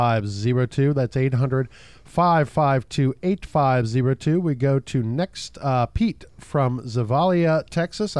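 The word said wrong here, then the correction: Zavalla: I hope I pronounced that town name correct. What's bugging you tonight, Pete? – Hi, good evening. It's Zavala. – Zavala, gotcha.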